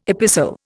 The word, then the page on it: epistle: Indian